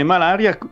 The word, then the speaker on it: malaria: C'era, esatto, il termine malaria penso che è stato coniato a Venezia. – Quindi si dice con quell'accento lì. – Malaria, sì, e si scriveva mal apostrofo aria, certo.